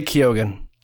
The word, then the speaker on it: Keoghan: Barry Keoghan, I think that's how you pronounce his name. – Just say Keoghan. – Keoghan? Why? Is it debated now? – No, well, it really isn't. Everyone just says Keoghan.